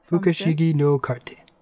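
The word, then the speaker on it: Fukushigi no karte (不思議のカルテ): So, our first song, and well, correct me if I'm wrong, is Fukushaki no Karte. How did I say that? – No cart. – Fukushigi no karte.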